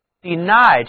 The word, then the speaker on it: deny: Deny 这个词，字母 Y 读作双元音 I，它的重音呢放在第二个音节上，请跟我读 deny。